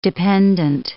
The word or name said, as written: dependant